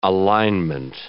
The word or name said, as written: alignment